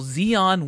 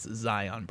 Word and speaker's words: Zion: Luckily, the guy he was with corrected him angrily and said, "It's Zion, bro."